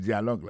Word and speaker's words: dialogue: Tande yon parti in the dialogue, nan dialogue la. Alors mot dialogue ça c'est dialogue. Ça ne carrément manqué là, nous carrément manqué que l'accent tonique là tombé sous premier syllabe là.